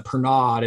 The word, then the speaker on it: Pernod: It's spelled P-E-R-N-O-D, but when we were in France, so we went to France, to Annecy we had the international premiere of Clay Dream there, and I ordered one and they called it Pernod. So I mean, I don't know like how we pronounce it here, but Greg, do you know what I'm talking about? – Yeah, I do.